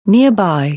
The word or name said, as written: nearby